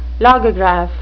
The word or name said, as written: logograph